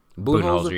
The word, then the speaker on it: Budenholzer: So, but can we give some credit to what is it, Budenholzer? That's how you pronounce his name. – Budenholzer, yeah.